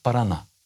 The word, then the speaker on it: Парана: Ну, е, правильно буде наголос на останньому складі, буде штат Парана.